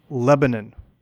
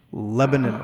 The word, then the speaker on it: Lebanon: Let's call it Lebanon, which is how it's pronounced locally. Lebanon, Oregon.